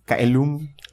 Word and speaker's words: caelum: Like modern Vatican Latin would probably pronounce that with a ch sound. Chailum or something.